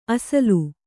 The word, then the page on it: ಅಸಲು: asalu